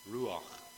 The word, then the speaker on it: ruach: So the Old Testament was written in Hebrew, and the word that they use for spirit is ruach. I've heard people say it, and I think you have to roll the R and spit at the end, and that's kind of how you say it.